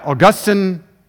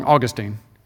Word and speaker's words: Augustine: I mean, you got Augustine, or if you want to say it wrong, Augustine. We all say Augustine, but Augustine, Augustine. Yeah, Luther, Jonathan Edwards, maybe Piper, when history is done.